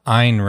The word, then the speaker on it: Ayn: It is Ayn Rand, right? – Yeah. – Okay, yeah. Yeah, I was trying to look that up beforehand. So, ha. – It rhymes with mine, like Mein Kampf.